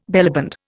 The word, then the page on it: bellyband: Indian